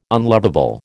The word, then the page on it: unlovable: American English